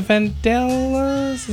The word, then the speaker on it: Vandellas: Martha and the Vandellas, is that how you say it? – Mm-hmm.